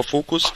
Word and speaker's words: Focuz: Ich hatte ja gerade schon gesagt, Sie haben ein solches gefunden, nämlich Focus Labs, geschrieben Focuz, also eine Variante von Focus, spricht man über Focus.